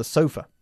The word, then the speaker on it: sofa: The sofa, no, the sofa. – The sofa. – Y nosotros enfatizamos la otra sílaba, la primera, ¿ok?